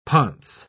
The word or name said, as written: ponce